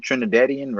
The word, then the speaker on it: Trinidadian: Wait, that's how you say it, Trinidadian, right? – Right. – I was right.